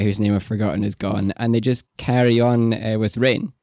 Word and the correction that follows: Rian: or Ryan. His name is Ryan. I say Rain, I'm sorry.